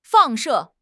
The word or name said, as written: 放射